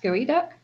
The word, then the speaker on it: geoduck: Just don't look up, you spell it like geoduck, but it's pronounced gooey duck.